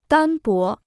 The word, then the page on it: 单薄: dān bó